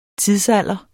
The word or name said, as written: TIDSALDERENS